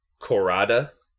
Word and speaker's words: Khorada: Fortunately, two bands have now risen from the ashes in the forms of Korada, which is spelled K-H-O-R-A-D-A, and that's a band that includes four of the previous members of Agaloc, including Don Anderson, J.